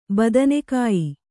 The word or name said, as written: ಬದನೆಕಾಯಿ